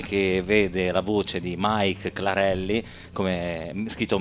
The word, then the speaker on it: Make: Mike è nata in Germania, quindi porta questo nome tedesco, si scrive Make e si legge Mike Clarelli, cantante, autrice, direttrice musicale del coro di donne migranti Le Chemin de Femmes.